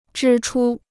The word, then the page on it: 支出: zhī chū